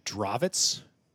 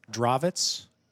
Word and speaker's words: Drawiec: There's no logic between Polish spelling and pronunciation. This guy's name is David Drawitz. – Oh, boy. – Drawitz. Let me say that a third time. Drawitz. And I'm going to spell this now.